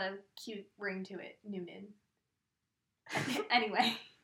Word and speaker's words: Noonan: Yeah, Noonan.